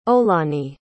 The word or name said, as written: olaniyi